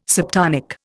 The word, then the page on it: subtonic: Indian